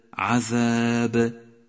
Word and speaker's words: عذاب: لاحظ حرف الظاء في كلمة الظالمين، الظالمين، ولاحظ حرف الذال في كلمة عذاب، عذاب. حرف الظاء وحرف الذال يشترك معهما حرف الثاء، عند نطقهما يجب أن يلامس رأس اللسان طرفي الثنيتين العليين وأن يبرز قليلا حتى يمكن أن يراه الناظر، هكذا إذ إث.